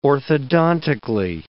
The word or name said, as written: orthodontically